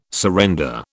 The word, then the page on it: surrender: British English